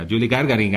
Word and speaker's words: Yuri Gagarin: Yuri Gagarin, a ver si ahora lo he dicho bien. – Sí, correcto.